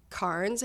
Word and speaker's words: Cairns: And then when we got there, we flew into Cairns, I believe you pronounce it, which is where the Great Barrier Reef is, like on the outskirts of it.